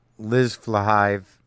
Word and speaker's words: Flahive: Now, the woman, the two women who wrote these, uh, Carly uh, Carly Mensch and Liz Flahive, I believe is how you say her last name. It's a tricky last name. It's F L A H I V E.